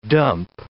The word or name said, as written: dump